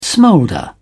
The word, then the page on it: smolder: British English